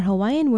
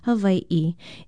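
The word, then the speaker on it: Hawaii (Hawaiʻi): It should be spelled with an okina or glottal stop between the two letters I and pronounced either as Hawaii or Hawaii. Yes, either is okay. Language experts say you can pronounce it with either a W or a V if that sound follows an A.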